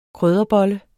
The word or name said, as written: krydderbolle